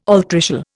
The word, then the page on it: altricial: Indian